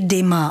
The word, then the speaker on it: Dumas: Píše se Dumas, a měli bychom se tedy držet té výslovnosti Dima, ale to bychom skloňovali podle vzoru předseda.